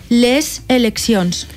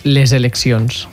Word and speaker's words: les eleccions: Que hauríem de pronunciar les eleccions. – Les eleccions. – Exacte. La S sonora de l'article és que és sorda, solta, passaria a ser sonora perquè la següent paraula comença per vocal, que és sonora, per tant.